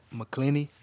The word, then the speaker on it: McKinney: McKinley? How you say it? – McKinney. – McKinney, my bad.